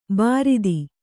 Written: ಬಾರೂದು

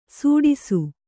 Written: ಸುಡಿಸು

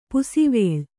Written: ಪಾಸುವಲೆ

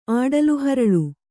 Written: ಆಡಲುಹರಳು

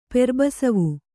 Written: ಪೆರ್ಬಸವು

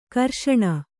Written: ಘರ್ಷಣೆ

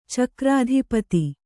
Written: ಚಕ್ರಾಧಿಪತಿ

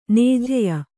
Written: ನೇಜ್ಯೆ

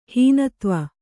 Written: ಹೀನತ್ವ

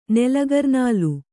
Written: ನೆಲಗರ್ನಾಲು

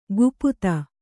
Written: ಗುಪುತ

